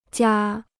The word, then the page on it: 家: jiā